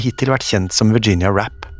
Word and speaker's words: Rappe: Nå la hun til en ekstra E i etternavnet, og endret samtidig uttalen til Rappay. Hun følte selv at Virginia Rappay hørtes ut som en langt mer verdensvant kvinne enn Virginia Rapp.